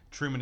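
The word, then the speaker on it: Trueman: Just point out, because people regularly get our name wrong, Truman is spelt with an E, so it's T-R-U-E-M-A-N.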